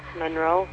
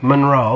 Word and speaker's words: Monroe: Monroe. – Monroe.